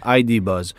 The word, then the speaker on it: ID Buzz: Vaut mieux le prononcer en anglais, ID Buzz.